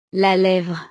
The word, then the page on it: la levre: French